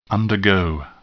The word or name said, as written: undergo